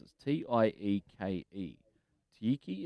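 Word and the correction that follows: Tieke: is that how you... – Te Iki. – Yeah.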